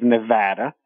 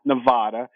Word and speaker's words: Nevada: the state of Nevada. As Brian would say, it's not Nevada, it's Nevada.